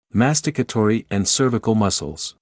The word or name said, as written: masticatory and cervical muscles